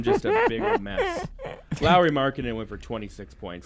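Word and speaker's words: Lauri: It is pronounced Lowry.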